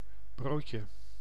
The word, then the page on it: broodje: [bɾoːd.jǝ]; /ˈbroːtjə/